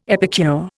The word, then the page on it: epicure: Indian